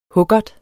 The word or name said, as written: huggert